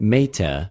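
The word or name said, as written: μήτε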